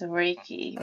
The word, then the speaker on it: Reiki: Now, Reiki. I say it right? – Yes, Reiki.